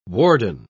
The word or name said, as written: warden